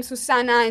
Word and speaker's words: Susana: But yeah, so Zana is a English pronunciation of my full name, Susana, in Spanish.